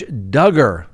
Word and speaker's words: Duggar: Josh Duggar rhymes with hugger.